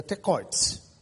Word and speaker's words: Tekoites: The Tekoites. Pronounce it that way.